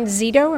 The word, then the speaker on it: Zsido: So I'm interviewing Dan Zsido.